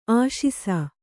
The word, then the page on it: ಆಶಿಸು: āśisu